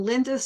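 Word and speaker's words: Lynda: And Linda spelled with a Y.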